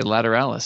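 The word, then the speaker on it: Lateralus: And I want to say that Tool's album, Lateralis, is that how you say it? Lateralis? – I believe that is. – Okay, Lateralis.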